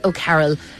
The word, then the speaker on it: O'Carroll: It was booked under O'Carroll. So how do you spell that? – O-C-A-R-R-O-L-L.